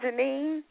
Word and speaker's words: Jennene: Am I pronouncing your name right, Jennene? – Yes, that's correct.